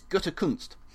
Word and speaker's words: Gutekunst: I'm going to go with um, Brian Gunterkust. – Gunterkust, that's it. That, that's I'll take your word on how to pronounce As, that. Uh, sorry, I've got that wrong. Sorry, it's Gutekunst.